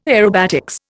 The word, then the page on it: aerobatics: Indian